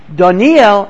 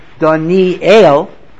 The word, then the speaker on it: Daniel: We pronounce the name Daniel as if the Tzerei is under the Aleph. But if you look in the book of Daniel, it is Daniel. Like, you know, Harvard, Yale. Daniel. It's interesting that that's not how we pronounce it, but that is the Nikud in the name.